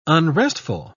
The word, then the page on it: unrestful: British English